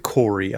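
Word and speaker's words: KOORUI: K O Corey Corey is that how you pronounce it? K O O R U I. I went I went with Corey on this.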